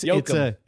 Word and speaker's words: Jochen: J-O-C-H-E-N-B-U-C-K. – Joachim. – Oh, Joaquin Buck. No, it's Jochen. It's probably Jochen.